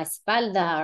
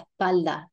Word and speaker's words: espalda: Eh, moving on, we've got back, la espalda, or as it would probably be pronounced in Puerto Rico, la espalda. Eh, that, little breath out sound, la espalda.